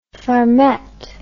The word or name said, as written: farmette